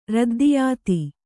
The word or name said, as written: ರದ್ದಿಯಾತಿ